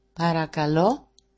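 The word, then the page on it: parakalo: Greek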